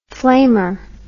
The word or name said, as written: flamer